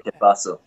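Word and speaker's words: Qué Paso: is that the right way to say it? Que Paso. – Yeah, Que Paso.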